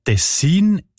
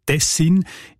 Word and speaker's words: Tessin: Die Variante Tessin ist häufiger. Die ist im Schweizerdeutschen Wörterbuch Idiotikon belegt im Kanton Bern, in der ganzen nördlichen Schweiz, aber auch im Innerschweizer Kanton Schweiz und das Siselen und das Unterschächen im Kanton Uri. Und auch im Hochdeutschen sieht man Tessin. Die Variante mit der Betonung auf den ersten Silbe und mit kurzem I, also Tessin, ist seltener.